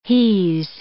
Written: he's

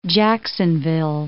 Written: Jacksonville

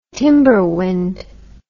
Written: timberwind

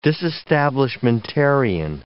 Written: disestablishmentarian

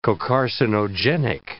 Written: cocarcinogenic

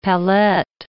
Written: pallette

